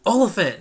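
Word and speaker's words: Olyphant: Oliphant, that's right. I don't know. Yeah, so Oliphant. – It sounds like elephant.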